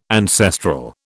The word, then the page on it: ancestral: British English